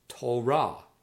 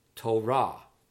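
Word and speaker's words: Torah (תורה): Word 39, which means law, or a better translation would be instruction, is pronounced Torah, Torah, Torah.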